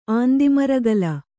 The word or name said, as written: ಆಂದಿಮರಗಲ